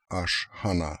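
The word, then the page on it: Ashhana: Russian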